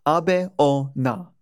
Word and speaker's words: Abeona: And our next spirit is Abeona, pronounced Abe or Na.